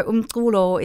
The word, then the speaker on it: Unkulo: In 2010, then you set up Unkulo. Am I pronouncing it correctly? – It's a tricky one. So Unkulo is a Xhosa word, which means art music.